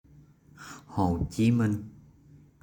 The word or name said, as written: Hồ Chí Minh